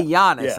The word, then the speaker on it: Giannis: Giannis. I can say Giannis. Yeah. I can say that, right? – Giannis. Yeah, you say Giannis.